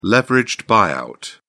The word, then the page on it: leveraged buyout: British English